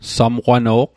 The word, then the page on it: samqwano'q: Mi'gmaq